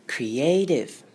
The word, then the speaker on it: creative: 接下来是 TIVE 的部分， T 的话呢要念非常轻声的 D，念成 D，然后就这样舌头往上顶，然后滑过去，像这样子才对哦。 Creative Creative。